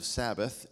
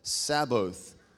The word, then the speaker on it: Sabaoth: It's not a mispronunciation of Sabbath. It's S-A-B-A-O-T-H. It's pronounced Sabaoth, and it means Lord of hosts.